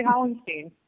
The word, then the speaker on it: Hollenstein: I say Hollenstein.